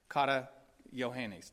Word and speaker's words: Kata Johannes: Kata Johannes, that's how you say it.